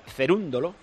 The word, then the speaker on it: Cerúndolo: Ah, bueno, y tenemos a otro español en semifinales del torneo de tenis en Buenos Aires, Albert Ramos contra Cerúndolo, que se pronuncia así, el argentino.